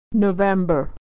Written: November

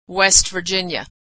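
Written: West Virginia

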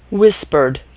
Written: whispered

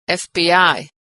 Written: FBI

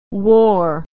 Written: wore

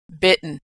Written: bitten

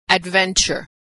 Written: adventure

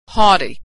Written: haughty

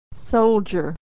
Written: soldier

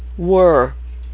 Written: were

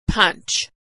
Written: punch

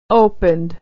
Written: opened

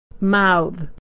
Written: mouthe

